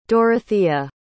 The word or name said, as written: Dorothea